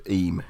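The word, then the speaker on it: Eyam: And it's actually, not obviously, but it's pronounced Eme, yeah.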